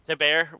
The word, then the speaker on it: Thibert: There was a thing by Art Thibert? – Thibert, whatever.